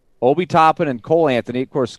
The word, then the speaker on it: Obi Toppin: Obi Toppin. – Obi Toppin.